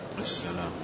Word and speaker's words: السلام: وهذا مع الأسف أنه كثير من يعني بعض الناس من مصر أو من غير مصر، إذا جاء السلام عليكم يعني حتى لا ينطق اللام، ودائما يحصل هذا منهم. و نذكر لهم أنه لا بد أن تخرج اللام، لا بد أن تنطق باللام. – السلام.